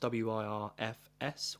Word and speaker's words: Wirfs: So for the NFC, you had Demarcus Lawrence, Penne Sewell, Tyler Smith, Montez Sweat, and Tristan Wirfs. I believe that's how it's pronounced. W I R F S Wirfs.